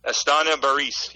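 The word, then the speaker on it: Barys Astana: I want to make sure I'm saying this right. Estania Baris? Am I right there? – Estania Baris, yes.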